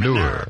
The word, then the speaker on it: manure: Ma, M-U-H-N-O-O-R is the pronunciation guide. Ma-nur.